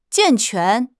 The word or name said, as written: 健全